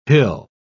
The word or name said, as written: hill